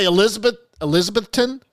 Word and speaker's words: Elizabethton: Elizabethton.